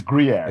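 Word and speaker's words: Gruyère: It's Gruyere. – It's Gruyere. – It's G-R-U-Y-E-R-E.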